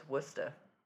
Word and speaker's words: Worcester: Because there's Worcester, Massachusetts, and that's how you say it. – And it's spelled Worcestershire? – It's spelled Worcester. But it's Worcester. There's no R.